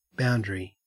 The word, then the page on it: boundary: Australian English